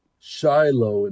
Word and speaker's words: Shiloh: So, so I'm sitting here, with ancient Shiloh in back of me. I mean, we call it by its Hebrew pronunciation, Shiloh, but this is ancient Shiloh right here.